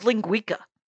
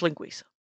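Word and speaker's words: linguiça: All right, and it's spelled linguica, but it is pronounced linguica.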